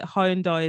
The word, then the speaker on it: Hyundai: That Hyundai is saying you got to call it Hyundai. It's not Hyundai.